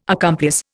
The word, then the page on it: accomplice: Indian